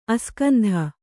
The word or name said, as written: ಅಸ್ಕಂಧ